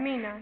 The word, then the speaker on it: Mignon: It's M-I-G-O-N. Something like that. Migon or Mignon. – Mignon.